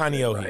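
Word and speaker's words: Kanahohe (Kāneʻohe): And the next day, we're at St. Anne's in Kanahohe, is it? I'm not sure I'm pronouncing Kanahohe. – It right. Kanahohe.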